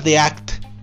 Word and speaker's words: The Act: lo voy a repetir, T-H-E espacio A-C-T. Se pronuncia The Act.